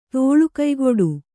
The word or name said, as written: ತೋಳುಕಯ್ಗೊಡು